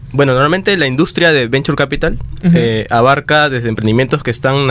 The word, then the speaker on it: Venture Capital: O sea, coméntanos un poco acerca de Adventure Capital. ¿Lo dije bien? – ¿Sí? Bueno. – Venture Capital.